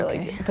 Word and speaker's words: bokeh: I'm like, oh, what's like bokeh photography or bokeh. Like, but wait, how do you say it? – Bokeh. – Oh my god. Our teacher said bokeh.